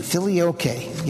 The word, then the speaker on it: filioque: which added the word philike to the Latin version of the Constantinople Creed. Thank you. Filioque. Yeah, I should look up the pronouncing. If I look them up, I have difficulty. – I know. It's filioque.